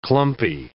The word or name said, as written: clumpy